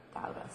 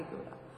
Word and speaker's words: Gouda: Gouda, sorry. I gotta be Gouda. John would be Gouda tonight. You pronounce it Gouda, right? – Gouda. Gouda. – No, Gouda.